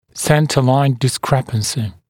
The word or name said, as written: centre line discrepancy